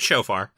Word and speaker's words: shofar: No, it's shofar.